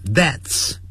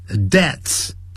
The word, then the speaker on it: deaths: causes C-A-U-S-E 加个 S 把 which 去掉 cause 就变成 causes 就变成 causing 造成引起 many deaths 本来是 deaths 但这个 deaths 难发这样的是 death death 啊，是无声的 death 然后发一个 s 所以呢就这样 deaths deaths 这样发音就可以了 deaths 不要念成 deaths，那不是有声的，是 deaths， deaths 就直接念成 deaths。